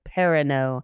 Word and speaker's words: Perrineau: The last time Harold Perrineau was in a movie, I was pronouncing his last name wrong. I went and looked up pronunciation. I apologize. Perrineau.